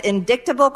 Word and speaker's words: indictable: To deal with the commission of indictable crimes and the abuse of power. – Oh, my God. – Yeah, the C's silent. – Indictable. – It's like GHT is the way it's pronounced.